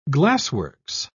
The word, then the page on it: glassworks: British English